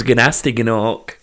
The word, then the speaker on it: Gnasty Gnork: I purposely pronounced it wrong as well, because technically his name's Gnasty Gnork, because they pronounce the G, don't they, all the way through that game.